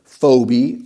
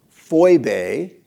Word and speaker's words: Phoebe: So verse 1 of 16 says, I commend to you our sister Phoebe or Phoebe, depending on how you want to say it, or Phoebe is how the Greeks would have pronounced it.